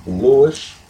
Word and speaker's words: Louis: Louis, L-O-U-I-S, Mizuka, M-A-Z-Z-U-C-A.